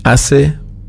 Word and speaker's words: hace: Hace una semana. Hace schreibt man mit H. Seit. Hace, das haben wir noch nicht gehabt.